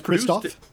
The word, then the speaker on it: Christoph: Features art by Inca Brand, Marcus Brand, Sylvia Chinstoff, I apologize for my pronunciations, and Franz Volwinkel. – Isn't that Christoph? – Christoph, yes it would be. Sylvia Christoph, thank you.